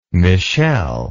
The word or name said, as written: Michele